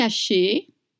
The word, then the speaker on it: haché: You don't pronounce the H in French. It's mute. So you don't say haché, you just say haché.